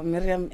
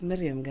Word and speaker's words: Miriam: why must you say it like that? – Can't we give you an African name also? Because Miriam is not an African name. – No, Miriam, it's Miriam, guys.